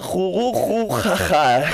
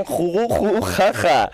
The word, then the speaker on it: Jurujujaja: Bueno, alors, Gamba, esta semana le traigo una propuesta de humor que, como no, ni más ni menos que la compañía Jurujujaja. Sí, Gamba, ¿ha oído bien? Jurujujaja. Para mí es casi imposible de pronunciar, ¿no? Es juru, que ru, cuando digo ru es con R.